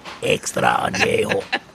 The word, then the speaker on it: extra añejo: Extra añejo.